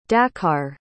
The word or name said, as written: dakar